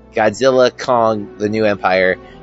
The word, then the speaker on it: Godzilla x Kong: The New Empire: There's Variety posted an interview with the publicist or something, and they were told that Godzilla X Kong: The New Empire should be properly said with a silent X, meaning you don't say the X. Godzilla Kong: The New Empire.